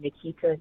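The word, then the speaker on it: Nequita: On Instagram is my name, Nikita Dowling, but it's spelled like Chiquita Banana almost.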